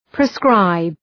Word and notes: proscribe: prəʋ’skraıb

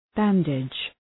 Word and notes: bandage: ‘bændıdʒ

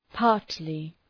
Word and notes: partly: ‘pɑ:rtlı

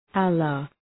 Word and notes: Allah: ‘ælə, ‘ɑ:lə

